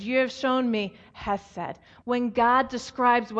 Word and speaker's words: hesed: The word for mercy that's used here in the Hebrew scriptures, it's a little word, it's pronounced hesed.